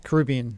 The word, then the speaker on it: Caribbean: The people from the cruise line tell me it's pronounced Caribbean.